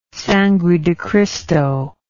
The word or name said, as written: Sangre de Cristo